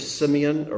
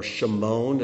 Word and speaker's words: Shimon: See, the first three letters of Simeon, or Shimon, as it should be pronounced, are the same as the word for hear.